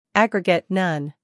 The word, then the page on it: aggregate: ˈæɡrɪɡət